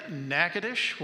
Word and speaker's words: Natchitoches: From there, the Lord led us to Louisiana, northwest Louisiana, Natchitoches, where Bill and Phyllis Collins are. If you see Natchitoches, it's not pronounced like it's spelled.